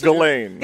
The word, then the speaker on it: Ghislaine: It's Ghislaine. – Ghislaine. – Ghislaine. The S is silent.